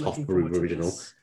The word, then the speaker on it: Hofbräu: Well, pronounced Hofbräu Original.